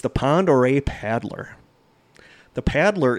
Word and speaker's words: Pend Oreille: And I'm glad I looked up how to say that because it looks like Pend Oreille. But it's French, yeah. – Okay. – It's pronounced Lake Pend Oreille.